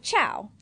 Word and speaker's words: ciao: It's spelled C-I-A-O. – Yeah, like chow.